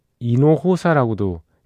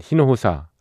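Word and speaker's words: Hinojosa: 예, 미국인이긴 합니다만, 예, 음, 티시 흰호사 라는 예, 티시 이노호사라고도 레티시아 이노호사라고도 하고요. 뭐 여러 이렇게 스페인어 발음이라 가지고 좀, 음, 좀 다르기는 합니다만 하여튼 우리가 티시 음, 히노호사로 알고 있는 예, 통기타 예, 예, 포 가수가 있습니다.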